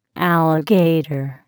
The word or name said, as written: alligator